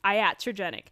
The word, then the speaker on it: iatrogenic: I just learned how to pronounce that word because I didn't know how to pronounce it in the episode that I filmed with Miranda. So iatrogenic. Iatrogenic means that it is a disease caused by the supposed cure or medical treatment, which in this case is the topical steroids.